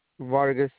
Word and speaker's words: Vargas: Vargas. – Can you spell that, please? – V-A-R-G-A-S. – V-A-I. G-A-S? – R. Yes. R-G-A-S.